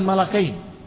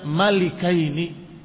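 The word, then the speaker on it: malikaini (مَلِكَيْنِ): Tetapi kana Abdullah ibn Abbas yaqra'uha malikaini bikasrillam. Bahwa Abdullah bin Abbas radhiyallahu ta'ala anhu membaca ayat ini bukan malakain, tetapi membacanya malikaini dengan kasrah di lamnya.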